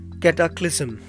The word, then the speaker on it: cataclysm: C-A-S-T-I-G-A-T-I-O-N. It means punishment, chastisement, reproof. The next word is catacalism. I spell it C-A-T-A-C-L-Y-S-M, catacalism. Cataclysm. It is cataclysm, actually cataclysm.